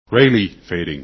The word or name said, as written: Rayleigh